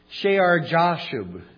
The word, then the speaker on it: Shear Jashub: Shear Jashub is how you pronounce that weird name.